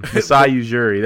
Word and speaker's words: Masai Ujiri: Messiah. – The guy. – I can't even say his name anymore. I'm gone. Masai Ujiri. There it is. Masai. Yeah. I said Massage.